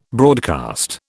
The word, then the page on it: broadcast: British English